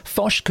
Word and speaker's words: Foch: So that's spelled F-O-C-H, but it's a Breton name, so it's pronounced Foch.